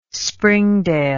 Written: springdale

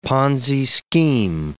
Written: Ponzi scheme

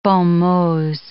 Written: bon mots

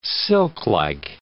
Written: silklike

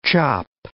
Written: chop

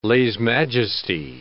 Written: lese-majeste

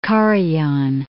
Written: Karajan

